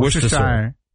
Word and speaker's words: Worcestershire: So you don't say the R, you don't say Worcestershire? – No.